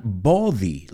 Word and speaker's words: body: It's not body, like oh, it's ah. Like when you go to the doctor and he says, open up your mouth and say ah. You go ah. Well, that's the same vowel sound.